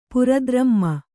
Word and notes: ಪುರದ್ರಮ್ಮ: pura dramma

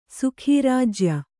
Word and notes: ಸುಖೀರಾಜ್ಯ: sukhī rājya

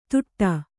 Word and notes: ದುಟ್ಟ: kannada

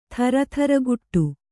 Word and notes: ಥರಥರಗುಟ್ಟು: Kannada

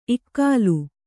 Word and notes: ಇಗ್ಗಾಲಿ: iggāli

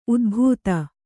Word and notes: ಊದಬತ್ತಿ: kannada

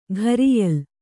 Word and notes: ಕರಿಯಾಲೆ: kannada

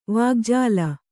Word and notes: ವಿಕಜ್ಜಲ: kannada

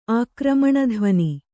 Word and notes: ಆಕ್ರಮಣಧ್ವನಿ: kannada

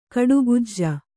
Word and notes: ಕಡುಗಜ್ಜ: kaḍugajja; Kannada

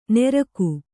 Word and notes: ನೆರ್ಗು: nergu

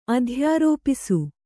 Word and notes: ಅಧ್ಯಾರೋಪಿಸು: adhyārōpisu

